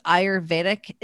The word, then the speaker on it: Ayurvedic: Could you explain to me what Ayurvedic is? If I'm saying that right? – You are.